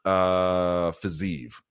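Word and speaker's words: Fiziev: I favor Fazeev. That's how it was told to me, how it's pronounced, by the way.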